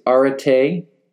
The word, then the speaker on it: arete: the Greek is arete, A-R-E-T, long E, moral excellence.